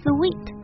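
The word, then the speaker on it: suite: 说到这个不发音的 e 呢，我们知道套装 suit 它读成 suit，然后呢它词尾加一个 e，大家可能知道加的这个 e 是不发音的，所以会觉得套房这个词呢是不是还是应该读 suit 呢，这样就是不对的啊。它的发音呢和甜蜜的也就是 sweet 那个单词的发音是一样的，所以以后到酒店订房间的时候千万就不要再说错了。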